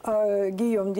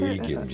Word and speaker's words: Гийом: Поэтому надо сказать, что отец Алиеноры, Гийом X, он в принципе... – Или Вильгельм X. – Да, ну или Вильгельм, или Гильем, разные здесь есть варианты произношения.